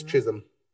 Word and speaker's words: Chisholm: Is it Chisholm or Chisholm? Am I mispronouncing it? It's Chisholm. – Oh, I am mispronouncing that. Thank you. Yes. – The L is silent.